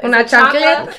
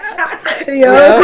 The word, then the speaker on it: chancleta: Una chancleta. – Chancleta. Chancleta, yeah. I saw a post on social media, like, what did you get booked with? And all the Hispanic people was like, chancleta.